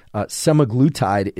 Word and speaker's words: semaglutide: So this is kind of the modern version of GLP-1 is pharmaceuticals of GLP-1 metabolism are drugs such as semaglutide is the way I would pronounce it, S-E-M-A-G-L-U-T-I-D-E. Semaglutide, but that's not the way you pronounce it, but semaglutide is the way that it's been described on the internet.